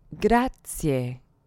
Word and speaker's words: grazie: Here's one that I often hear mispronounced. Thank you. – Grazie. – Grazie. Make sure you have an E ending on grazie and not A or E.